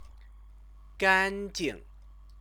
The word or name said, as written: ganjing